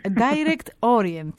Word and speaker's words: Direct Orient: Είναι το βιβλίο σας λοιπόν, να το πω με γαλλική προφορά ή με αγγλική? – Με αγγλική. – Direct Orient λοιπόν, Ναι, ωραία, σωστά.